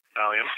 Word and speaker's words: Allium: I have a bunch of, I didn't even know how to pronounce it, Allium? – Allium, yep.